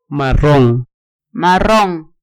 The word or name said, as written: marron